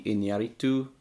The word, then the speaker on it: Iñárritu: Iñárritu, which is fun to say.